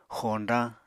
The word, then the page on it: xoñdañ: Apatani